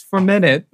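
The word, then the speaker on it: 4Minute: Yeah, I hope I'm pronouncing this correctly. 4 minute. – Yep. – Is it for minute? – It's for minute.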